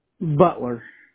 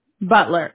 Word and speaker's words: Butler: And then you said Potler? – Butler. – Butler, okay. – Yeah, Butler.